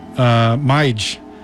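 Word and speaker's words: Mij: But he actually pronounces it on the album Mige. So it's his first name spelled backwards. So it's M-I-J, Mige. Sort of like Uma Sumac.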